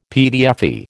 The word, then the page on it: PTFE: American English